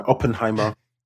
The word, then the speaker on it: Oppenheimer: Oppenheimer.